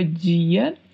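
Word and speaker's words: día: significa dia. A diferença é que é escrito com um acento agudo no i e a pronúncia é di. Em espanhol, não temos a pronúncia di.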